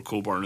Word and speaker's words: Cockburn: C-O-C-K-B-U-R-N. It's never pronounced Cockburn because people don't want to hear that pronounced. They go for Coburn as like some weird middle alternative, because this is the same thing with Olivia Wilde, because Olivia Wilde's actual last name is Coburn.